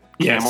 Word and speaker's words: Camel: Camel? Yes. Yeah, just Tony like the Camel. Animal.